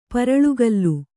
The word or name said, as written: ಪರಳ್ಗಲ್ಲು